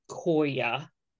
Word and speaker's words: Koya: So I do not know much about Koya, and I'm now questioning if I even said it right. – Said it perfectly.